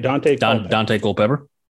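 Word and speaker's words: Daunte Culpepper: Don't hold me to all this, but I think they played, from Central Florida, Duante Culpepper. Yeah, am I saying that right? – Dante Culpepper? – Yep, I think he played against Nebraska when he was in college.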